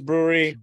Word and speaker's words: Bruery: I think because it's not spelled like brewery, but I think it is brewery. – B-R-U-E-R-Y. – It's pronounced a brewery. That's how it's pronounced.